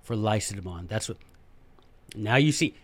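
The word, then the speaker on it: Lacedaemon: I am by nature Spartan, not laconic, but Lycidemon is how they pronounced that. That's what they called their own city, by the way, Lycidemon.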